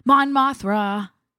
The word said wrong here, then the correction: Mon Mothma: Yeah, if you saw the trailers, Mon Mothma is definitely in there.